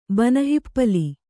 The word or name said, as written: ಬನಹಿಪ್ಪಲಿ